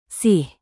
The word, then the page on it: sih: Indonesian